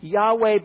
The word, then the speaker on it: Yahweh: That pronunciation, Yahweh, by the way, is those four consonants with the vowel points of Adonai placed in. And it's the best guess that we have.